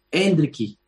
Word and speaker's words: Endrick: ¿Endrick o Endricky? – Endricky. – Endricky. No acertó nadie.